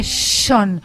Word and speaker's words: Sean: Se escribe Sean y sí. Se dice Sean. Pero cuando es Lennon, es John Lennon.